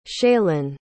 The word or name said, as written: shaylon